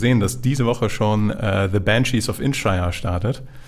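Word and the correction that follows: Inisherin: Und ich habe das hundertprozentig falsch ausgesprochen. Mhm. Da heißt es Inishirin. Inishirin. Inishirin.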